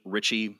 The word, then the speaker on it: Ritchie: Ritchie. My last name is spelled R-I-T-C-H-I-E.